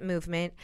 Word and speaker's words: MVMT: Movement watches, and it's MVMT, but they pronounce it movement.